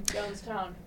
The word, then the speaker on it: Jonestown: But we also heard Mrs. Morality by Bar Italia, It Girl by the Brian Johnstone, John Stone Massacre. – Jonestown.